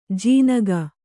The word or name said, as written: ಜೀನಗ